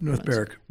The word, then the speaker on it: Berwick: I think I looked up North Berwick. – Berwick. So it's spelled with a W, not really pronounced that way.